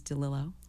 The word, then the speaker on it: DeLillo: So the proper pronunciation is DeLillo. – Oh, wrap your tongue around that Italian name.